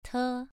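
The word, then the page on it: te: tone 2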